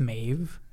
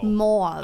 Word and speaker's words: Mauve: Is it pronounced Maeve? – No, there's a U. – Oh. – Mauve.